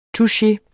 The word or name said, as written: touche